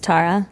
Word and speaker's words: Tara: Yeah, it's a common mistake, and it's pronounced Tara.